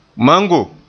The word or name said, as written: mango